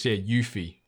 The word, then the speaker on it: Yuffie: It's, Yuffie is how you pronounce it. So I think you nailed it.